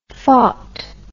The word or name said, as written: faut